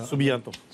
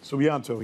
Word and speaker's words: Subianto: This is Sabiunto, right? Did I pronounce his name right? – I'm going to... Subianto. – Subianto.